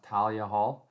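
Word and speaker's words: Thalia Hall: Katie bought me tickets for a show at, I don't know if it's pronounced Talia Hall. – That sounds about right. – Yeah, it's TH, but I didn't know if it was Talia or Talia.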